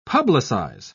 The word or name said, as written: publicize